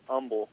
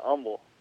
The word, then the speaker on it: Humble: It's actually pronounced Humble. – Oh, it's Humble. Yeah. Really? – Yeah, Humble. But yeah, for people who aren't there, everyone calls it Humble, but I figured I'd give you inside information there that way if you ever run into somebody.